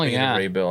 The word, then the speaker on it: Beta Ray Bill: Bantha Ray Bill, Beta Ray Bill, I always say his name wrong.